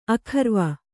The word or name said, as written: ಅಗೌರವ